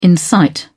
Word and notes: incite: British English

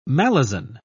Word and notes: malison: British English

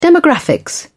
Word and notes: demographics: British English

